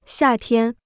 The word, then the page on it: 夏天: xià tiān